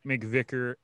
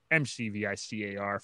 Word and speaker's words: McVicar: And if you want to find me, it's at Nick McVicar, McVicar for that last name, because I know people spell it wrong all the time. – There's no K. – There's no K. It's also not MacGyver.